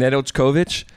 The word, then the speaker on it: Nedeljkovic: Nedeljkovic.